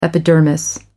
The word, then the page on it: epidermis: American English